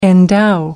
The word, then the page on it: endow: American English